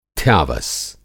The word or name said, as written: थ्यावस